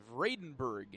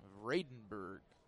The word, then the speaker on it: Vradenburg: Vredenburg, in case you were wondering. 1-0. Called strike on the outside edge. Makes it 1-1. Vredenburg is spelled V-R-A-D-E-N-B-U-R-G. Vredenburg.